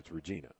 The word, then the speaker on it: Regina: It's not a long I. It's Regina.